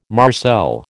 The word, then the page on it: marcel: American English